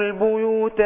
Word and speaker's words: البيوت: كتير بيغلطوا لأما ينطق البيوت يكسر الباء لأما ينطق الباء صح وبعدين ايه نضمها وبعدين يجي في الياء ايه يعومها ما ايه حققش مخرجها لا أنا عايز أضبط الباء أضمها وبعدين أحقق مخرج الياء البيو البيو كده